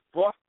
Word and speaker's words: pho: Vietnamese pho? – Pho. P-H-O. Pho. It's pronounced pho.